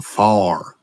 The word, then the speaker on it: fire: These things, they got some far. Some what? Some far. – Yes. – F-I-R-E. Far. That's how they say it in West Virginia.